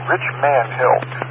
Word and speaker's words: Richmond Hill: It's not spelled Rich Man, but I pronounce it that way. Rich Man Hill.